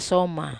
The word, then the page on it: ai soma: Wamesa